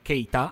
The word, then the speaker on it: Keita: Mi sembra di poter dire che, che Keita Keita, anzi senza l'accento, eh, è un, un, giocatore estremamente duttile, eh, che può ricoprire tantissimi ruoli, chiesto da Spalletti, matrimonio perfetto, no Roberto?